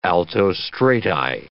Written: altostrati

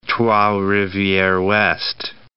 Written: Trois-Rivieres-Ouest

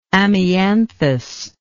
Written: amianthus

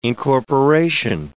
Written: incorporation

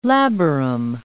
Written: labarum

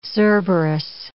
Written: Cerberus

